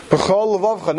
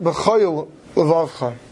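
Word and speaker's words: b'chol levavcha (בכל לבבך): If you don't, because you have to make sure that you pronounce it b'chol levavcha, not b'choyl levavcha.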